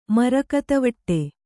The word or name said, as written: ಮರಕತವಟ್ಟೆ